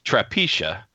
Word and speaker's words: Trapiche: It's Trapiche. Think of Propecia. – I was gonna say. – With the T.